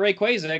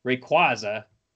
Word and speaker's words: Rayquaza: All right, I got no right to try and pronounce Pokemon that I think is correct, because it's been established I was wrong about Rayquaza, because it's Rayquaza.